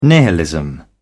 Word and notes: nihilism: British English

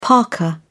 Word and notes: parka: British English

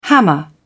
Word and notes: hammer: British English

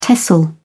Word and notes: tesl: British English

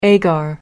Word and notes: agar: American English

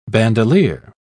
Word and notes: bandoleer: British English

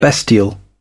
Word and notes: bestial: British English

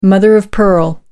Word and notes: mother-of-pearl: American English

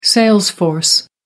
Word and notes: sales force: American English

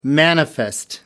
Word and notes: manifest: American English

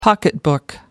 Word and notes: pocketbook: American English

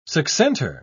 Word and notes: succentor: British English

